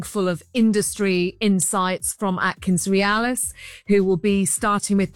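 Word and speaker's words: AtkinsRéalis: Have I said that right? Is that right, Atkins Realis? – That's perfect, Richard. Yeah, absolutely perfect.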